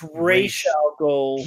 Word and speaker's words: Ra's al Ghul: You're pronouncing it wrong. – He's pronouncing it wrong. Ra's al Ghul. It's Ra's al Ghul.